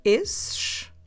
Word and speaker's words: esses: For instance, I will have to say esses. And how do we spell it? So just like we spelled esse, but with S at the end. So, esses, como se escreve? E, S, S, E, S. Esses.